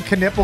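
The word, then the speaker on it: Knueppel: Are you just saying Knipple because it rhymes with nipple? – Kind of. – Or is that a real thing? – That's how they pronounced it. – Yeah, that's how you pronounce it.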